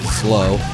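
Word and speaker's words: Zlo: Flow once pronounced it, well, I've been pronouncing it Z-L-O. I now know it's pronounced Slow from Estonia.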